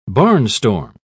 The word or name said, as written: barnstorm